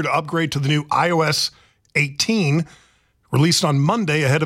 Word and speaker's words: iOS: I'm assuming it's pronounced iOS. – Yeah, like the operating. It's I for iPhone, iOS operating system.